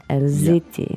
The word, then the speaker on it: elles étaient: But because elle is plural, you make that liaison there. Can you say it? – Elles étaient. – Yeah, the z sound. Elles étaient.